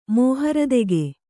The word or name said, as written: ಮೌಹೂರ್ತಿಕ